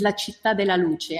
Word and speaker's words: La Città della Luce: So one big partnership we have is with La Città della Luce. I'm pronouncing it in its Italian way, which stands for the City of Light.